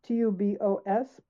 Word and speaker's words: tubos: Yeah, well, tubos, TUBOS, but the S is pronounced with a T, tubos.